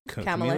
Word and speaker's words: Kamala: A Camila is it? – Kamala Harris. – Is it Kamala Harris? – Yeah. – Damn, I've been mispronouncing her name the entire time.